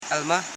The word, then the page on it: elma: el.ma